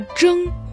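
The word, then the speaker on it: 争: Слово «джэн» произносится также в первом тоне.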